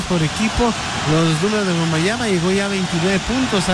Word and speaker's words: Wembanyama: En el segundo medio del partido, ahí está la gran diferencia hoy para Messier Wembanyama, como dice que se debe pronunciar en francés.